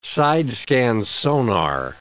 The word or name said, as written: side-scan sonar